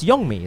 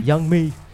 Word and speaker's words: Youngmi: It's Youngmi, isn't it? – No, it's Youngmi. – Youngmi.